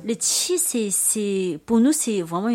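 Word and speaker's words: qi (气): Le qi.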